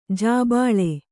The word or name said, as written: ಜಿಬ್ಬಳ